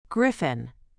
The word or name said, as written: griffin